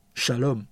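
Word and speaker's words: Shalom (שלום): 和平在希伯来文是 Shalom，读音的翻译是沙龙，而这个词语大部分的时候都被翻译为平安。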